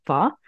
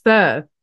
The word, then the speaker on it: pho: It's actually pho. Really? no, it's fur. Fur.